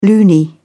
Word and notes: loony: British English

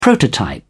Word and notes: prototype: British English

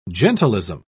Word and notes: gentilism: British English

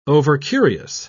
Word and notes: overcurious: British English

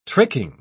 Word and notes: tricking: British English

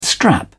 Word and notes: strap: British English

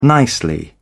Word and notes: nicely: British English

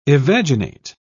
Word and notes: evaginate: British English

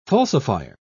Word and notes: falsifier: British English

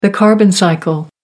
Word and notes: carbon cycle: American English

